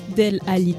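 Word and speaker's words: Dele Alli: Vous l'aurez deviné, c'est le Delhi Del Ali Challenge. Là, je vais me faire tuer par tous les gens qui aiment le foot parce que j'ai mal prononcé. Del Ali Challenge, hein.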